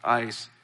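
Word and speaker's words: ice (εἰς): When the Greek, they use the word in, they use the word EIS, which is pronounced ice, and it means into.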